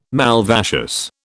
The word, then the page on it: malvaceous: British English